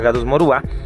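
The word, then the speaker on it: Gadus morhua: Morjúa con H, se pronuncia Gadus morúa.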